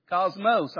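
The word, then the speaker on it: Cosmos (κόσμος): It's spelled the same. We just emphasize the O. Cosmos.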